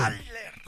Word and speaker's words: Shatner: Shatner.